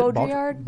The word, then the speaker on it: Baudrillard: Read some Jean Baudrillard. Is that how you pronounce it? – Baudrillard. – Baudrillard.